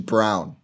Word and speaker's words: Braun: So EJ Liddell, Walker Kessler, Christian Braun, Kennedy Chandler, Terry Atari Eason, I think. And actually, sorry, it's Christian Brown, not Braun. It's spelled Braun, pronounced Brown.